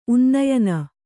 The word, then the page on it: ಉನ್ನಯನ: unnayana